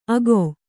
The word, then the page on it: ಅಗೊ: ago